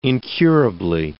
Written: incurably

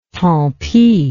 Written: tant pis

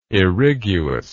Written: irriguous

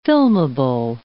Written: filmable

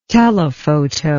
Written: telefoto

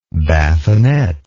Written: bathinette

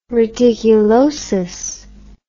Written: reticulosis

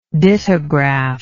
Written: dittograph

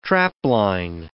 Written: trapline

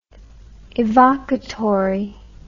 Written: evocatory

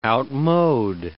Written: outmode

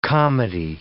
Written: comity

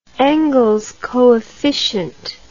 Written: Engels coefficient

